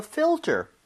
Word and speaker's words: filter: F-I-L. T-E-R. – Mm-hmm. – 자, 근데 발음 주의하셔야 되는 게 지금 fill 할 때 L 있죠? – 네. – 그리고 tur 할 때 R. – 와. – Right? L-R. 이 순서로 되어 있기 때문에 네. – 혀하고 입모양이 제대로 들어가야 돼요. 여러분, L 발음 오랜만에 갈게요. L 발음은 혀가 이렇게 구부러지는 게 아닙니다. 혀끝이 윗니와 입천장 사이 앞쪽을 밀어야 돼요.